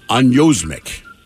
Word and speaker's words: anosmic: What sense would you not have if you were anosmic? Anosmic. A-N-O-S-M-I-C. And yes, I had to go to Google pronunciation thing.